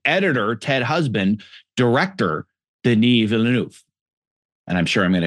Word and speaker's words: Denis Villeneuve: So for example, for now, let's just assume you're talking about, say how to pronounce his name again. I always get it wrong. I need to hear it. – I'm probably not pronouncing it as well. Denis Villeneuve.